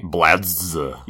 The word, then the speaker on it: Bladz: Yes, we are introduced the team X Blades, spelled with the letter X, but then B L A D Z. Blads. Yes, X Blads.